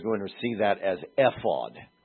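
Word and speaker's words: aphos (אפוד): Maybe from then on everybody just, okay, now we know that that's the word ephod. You would pronounce it aphos in Hebrew,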